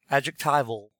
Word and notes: adjectival: American English

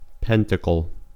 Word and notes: pentacle: American English

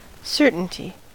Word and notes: certainty: American English

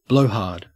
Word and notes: blowhard: Australian English

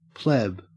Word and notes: pleb: Australian English

